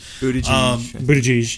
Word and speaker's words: Buttigieg: Buttigieg. – Peter. – I'll just call him Peter. – Buttigieg.